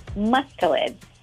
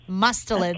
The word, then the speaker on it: mustelids: Mustelids. – Okay. Yeah. All right. We're on to it. – Mustelids. – Okay. – So what is a mustelid? – So mustelids are fur-bearing carnivores that inhabit terrestrial and aquatic environments, including marine environments.